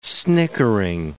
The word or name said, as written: snickering